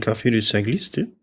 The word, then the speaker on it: Café du Cycliste: Ich würde sagen, da es eine französische Firma ist, würde ich aussprechen Café du Cycliste.